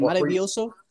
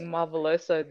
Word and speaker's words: maravilloso: How do you say it? Maravilloso? – Yes. – Yeah, I got my fucking Spanish down pat. – Tell me why I've been saying marveloso, this marvelous drop.